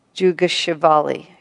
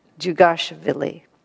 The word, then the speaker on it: Djugashvili: Stalin's actual name at birth was Yosef Vissarionovich Djugashvili. No, I didn't say that right. Djugashvili. I'm sure I pronounced that horribly.